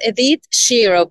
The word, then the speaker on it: Edith Shiro: And can you say your name for me? I know I'm not saying it properly. – No, you are. It's Edith Shiro. Perfect.